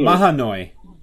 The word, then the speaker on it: Mahanoy: In Manoy City. Manoy. How do you say it? Manoy City? – Mahanoy. – Mahanoy City.